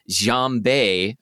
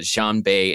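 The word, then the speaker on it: Xianbei (仙贝): And so that word is pronounced Xianbei. I'm sure I'm like getting the tones completely wrong, but Xianbei in Mandarin, and is spelled with totally different characters.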